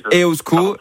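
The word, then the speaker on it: Eusko: Non, mais vous faites bien déjà, Iban, vous dites EOSCO, donc on va déjà le prononcer correctement, ça c'est la moindre...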